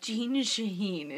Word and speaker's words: Shaheen: It's like, Jean Shaheen. It just rhymes.